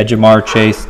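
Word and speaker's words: Ja'Marr Chase: Yeah, Jamar Chase, T.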